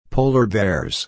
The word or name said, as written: polar bears